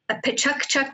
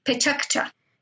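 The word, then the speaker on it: Pecha Kucha: It means chit-chat, and I think it's pronounced Pechukcha. – Mm-hmm. – Pechukcha.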